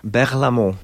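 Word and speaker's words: Berlaymont: Berlamont.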